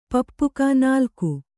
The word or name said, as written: ಪಪ್ಪುಕ